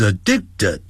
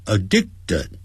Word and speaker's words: addicted: He is addicted、哦、要会念这个字，我要念慢点， Addicted Addicted T-E-D 念成 TED，不要念成 addicted，最后那个 d 的音是切掉 addicted。